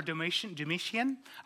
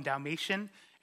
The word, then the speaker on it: Domitian: Emperor Domitian. I call him Dalmatian, and I like to pretend he liked dogs like a lot.